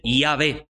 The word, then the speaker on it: Yahvé: O algunos ya cuando lo resumen un poco, lo contraen al nombre, dicen Yahvé, la pronunciación correcta.